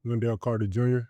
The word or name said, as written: Wendell Carter Jr.